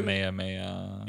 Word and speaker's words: Kamehameha: I don't even know how to say it. Comma chameleon. – I'll just keep going. – Whatever the fuck they do. – Kamehameha. – Yeah, you got it.